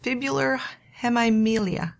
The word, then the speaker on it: fibular hemimelia: Oscar was born with fibular hemimelia.